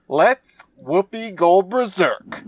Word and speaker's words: Whoopi Goldberg: let's Whoopi Goldberg.